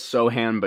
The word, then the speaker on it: Sochan: Sochan, right? Is that how you say it? Sochan? – It's Sohan, but yeah, that's...